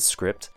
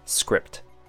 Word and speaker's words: script: And then fer, sounds like F-E-R, fer, decipher. Next one is script, one syllable. I chose this word because of its spelling. It's a very soft P sound for the word script. Be wary of that.